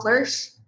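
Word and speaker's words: Floersch: We're working with Carl Flersch. That's how you pronounce his last name, and Vlad Zamfir.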